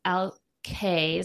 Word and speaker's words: Alki: So the back of it, of the label says Alki is how it's pronounced, meaning hope for the future, is not only the Washington state's motto, it is what Alki Wines embodies.